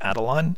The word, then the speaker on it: Attilan: Which member of the Inhumans enters the room to warn the others that the rocket is still headed for Adelon? And I'm going with, that's how that city on the moon is pronounced. I've always pronounced it Adelon, A-T-T-I-L-A-N.